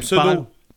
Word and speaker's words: Pseudo: Répète ça, puis prononce toutes les lettres, s'il te plaît. – Pseudo.